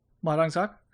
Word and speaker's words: Ma Dong Seok: It was Ma Dong Seok. – Ma Dong Seok.